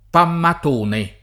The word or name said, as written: Pammatone